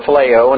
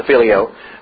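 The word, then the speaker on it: phileo (φιλέω): He uses the word, and I pronounce it phileo, and others pronounce it filio in other ways, but I'll just give you that one.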